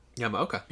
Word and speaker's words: Yamaoka: Yamaoka, yeah.